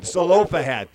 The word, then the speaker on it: Zelophehad: Zelophehad.